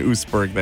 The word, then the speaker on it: Oostburg: It's two syllables in Oostburg there.